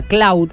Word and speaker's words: Claude: Es una inteligencia artificial que se llama Cloud, que se escribe Claude.